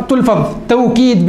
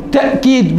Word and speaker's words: توكيد: التوكيد أيها الأحبة، هذه اللفظة تلفظ توكيد بالواو، وتلفظ تأكيد بالهمزة، وتلفظ تأكيد بتسهيل الهمزة، يعني يجوز أن تقول توكيد، ويجوز أن يقول تأكيد بالهمزة، ويجوز أن تقول تأكيد، هذا من حيث اللفظة.